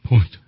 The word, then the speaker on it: Point: The T is silent. Point. Fernand Point.